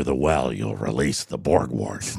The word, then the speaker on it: Borgwardt: Ryan Borgwart, I'm pronouncing it correctly, B-O-R-G-W-A-R-D-T, Borgwart.